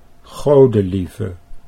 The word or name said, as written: Godelieve